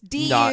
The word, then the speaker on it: Dublex: No, it's Dublex. – Duplex. – But it's pronounced like double X.